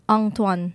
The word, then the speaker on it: Antoine: It'll always be because the way they pronounce the A-N, Antoine, on, and so yeah.